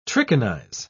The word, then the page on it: trichinize: British English